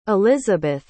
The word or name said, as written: elizebeth